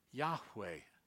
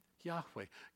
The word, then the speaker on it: Yahweh: How do you pronounce it then? Later on, the Masoretic Jewish scholars told us how to pronounce it. It is Yahweh. Yahweh. God's name is Yahweh.